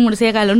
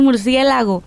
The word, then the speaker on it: murciélago: Y que nos burlamos de los niños chiquitos cuando dicen murciégalo. No, dice, no se dice así, se dice murciélago.